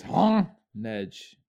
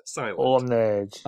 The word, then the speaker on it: Honedge: I think it's Honedge. – Huh? – The H of Honedge is the H of silent. – Honedge.